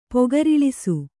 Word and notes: ಪೊಗರಿಳಿಸು: kannada

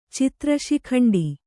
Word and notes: ಚಿತ್ರಶಿಖಂಡಿ: citra śikhaṇḍi

